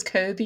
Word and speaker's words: Kobe: I pronounce it as Kobe, you know, like Kobe Bryant.